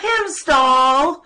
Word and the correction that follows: Heimdall: Oh, Oh, man. – It's Heimdall. – I have it written down, too, but I said it wrong, like, every time. Heimdall.